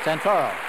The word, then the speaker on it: Santoro: Would you welcome, please, from the Orfila Winery, uh, Leon Santero, ladies and gentlemen. Santoro. Santoro, Italian. I got your name wrong.